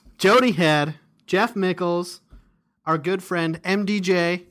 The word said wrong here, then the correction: Michaels: Jeff Michaels. Sorry. – Michaels. I know I popularized the mispronunciation of his name, but that is not how we say it.